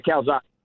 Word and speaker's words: Calzada: Coach, did you finally find your quarterback in Calzada, if I said it correctly? Calzada? – Yeah, Calzada. It is.